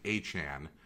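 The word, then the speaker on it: Achane: I mean, I guess he pronounces his name Achan.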